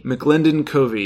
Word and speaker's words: McLendon-Covey: Wendy McLendon-Covey. – McLendon-Covey, yes.